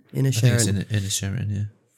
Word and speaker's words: Inisherin: Inisherin? How am I pronouncing that? – Inisherin, yeah.